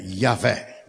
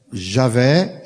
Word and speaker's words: Javé: Pronúncia desse nome é Yahvé. Javé, e depois em português, em língua latina, virou Jeová. Javé.